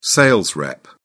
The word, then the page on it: sales rep: British English